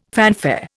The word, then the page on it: fanfare: Indian